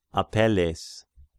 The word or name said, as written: Apelles